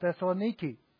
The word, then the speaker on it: Thessaloniki: And I think it's characteristic of what's going on in Thessalonica, or as they call it, Thessaloniki. That's how they pronounce it today.